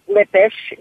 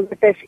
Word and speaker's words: Lippisch: I spell that J-A-C-Q-U-I, Jackie Thurlow Lippisch, J-A-C-Q-U-I-T-H-U-R-L-O-W-L-I-P-P-I-S-C-H. Boy, that's a mouthful. So it's Jackie Thurlow Lippisch.